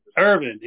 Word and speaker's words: Ervin: Is that how you say your name? – Ervin, E-R-V-I-N. – Ervin, yes, that's what I thought.